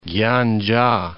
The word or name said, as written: Gyandzha